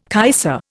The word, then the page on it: kaiser: Indian